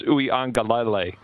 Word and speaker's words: Uiagalelei: It's Uiagalelei. Uiagalelei. – Okay. – It's not too bad. It's six syllables, but it kind of flows.